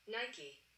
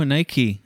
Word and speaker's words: Nike: Nike or Nike? – Oh, here. I pulled up the pronunciation website. – Play it up. – Listen. – Nike. – Oh, Nike.